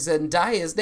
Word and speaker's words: Zendaya: turns out we have been pronouncing Zendaya's name wrong. It is Zendaya.